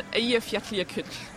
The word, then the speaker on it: Eyjafjallajökull: and it's pronounced Eyjafjallajökull.